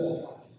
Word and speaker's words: Raw: 再比如说，这个大家应该都很熟，Raw，啊，其实我也不知道怎么读啊，R A W，我觉得应该读 Raw。